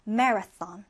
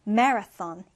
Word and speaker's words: marathon: 우리 TH 발음 주의해서 marathon, marathon 이렇게 발음해 주는데요.